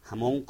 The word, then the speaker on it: Hamón: Hamón con G, o sea, G hebrea, se pronuncia como si fuera jamón de comer, y que significa gentío, multitud, tumulto.